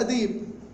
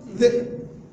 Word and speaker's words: ذئب: قال نضع أمر يحتمل لغة قريش ولغة غيره، فأخذ من الألف القطعة، وأخذ من الياء كرسي الياء، وأضعهم على بعضهم، فالذي ينطق بالتسهيل يقرأ ديب، والذي ينطق بالهمز يقرأ ذئب.